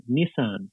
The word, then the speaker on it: Nissan: Nissan, or is it, I like how the Americans say it, Nissan.